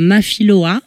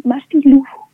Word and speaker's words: Mafilou: Alors, je le prononce mal, donc je vais te le laisser le prononcer, puisque moi, j'aurais dit euh, Mafiloa, mais ça se prononce comment C'est presque, c'est Mafilou. Mafilou.